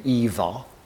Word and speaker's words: Ingvar: He would have pronounced his name Ivar and spelt it Ingvar.